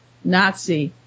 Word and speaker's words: Nazi: If the name had been shortened to Nati, N-A-T-I, Americans would not have known how to pronounce it. He means if it was pronounced Nazi, as the Germans would pronounce it. But, you know, we don't pronounce it that way, so it wouldn't work. So he thinks that's why it was spelled N-A-Z-I for Americans, coined by American journalists, to shorten the word National Socialist.